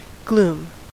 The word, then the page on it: gloom: American English